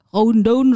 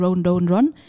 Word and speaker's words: Rhododendron: Rododendron. Run. – Rododendron. Run. – Also heißt er eigentlich Rhododendron, ne? aber die korrekte Aussprache dafür ist Rododendron.